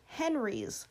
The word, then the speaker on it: HENRYs: And that term is called Henry's, okay? And that is spelled H-E-N-R-Y, all caps, okay?